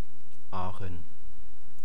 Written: Aachen

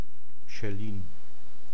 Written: schellin

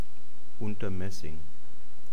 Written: Unter-Maessing

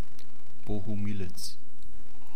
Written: Bohumilitz

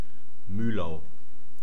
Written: muehlau

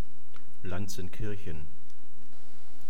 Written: lanzenkirchen

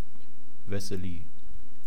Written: wessely

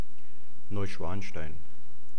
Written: neuschwanstein

